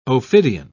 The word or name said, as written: ophidian